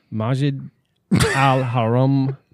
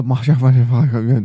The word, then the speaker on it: Masjid al-Haram: So, underneath, and I'm going to absolutely butcher this, but forgive me. Underneath the Masjid al-Haram Mosque, which is the holiest place in Islam. – The Masjid al-Haram Mosque. – They found... – Man, you did good.